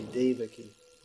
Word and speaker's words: Devaki: I think in Russian, as in English, the stress often comes on the penultimate syllable. Is it? So they'll say Devaki, and like this. In English, they often say Devaki. It should be Devaki.